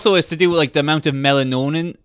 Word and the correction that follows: melanin: Melanin. Yeah, I think it's melanonin. – Melanin.